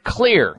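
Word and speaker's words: Xlear: The xylitol people over at Clear. Clear is spelled with an X, X-L-E-A-R.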